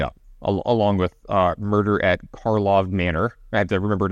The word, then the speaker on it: Karlov: It is not Markov Manor. It is not Markov Manor.